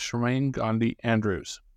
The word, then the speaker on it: Charmaine Gandhi-Andrews: I hope you enjoy this conversation with Charmaine Gandhi-Andrews.